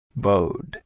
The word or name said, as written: Bowed